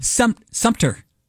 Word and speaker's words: Sumter: Sumner? – It's not Sumner. There's no N in it. – S-U-M-T-E-R. – Sumter.